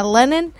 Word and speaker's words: Lennon: Lennon. Así como el cantante, yeah. Como el John Lennon.